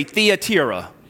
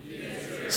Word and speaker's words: Thyatira: Everybody say Thyatira. Thyatira.